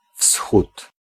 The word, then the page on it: rise: American English; [ɹaɪz]